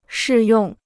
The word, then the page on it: 适用: shì yòng